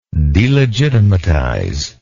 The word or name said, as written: delegitimatize